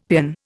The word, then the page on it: paean: Indian